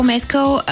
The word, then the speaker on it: Мескал: Но другото, което беше много симпатично, е, че Пол Мескал, който също... Пол Мескал, както е правил. правилното ударение. Пол Мескъл беше на премиерата на Urchin на Харис Дикинсън.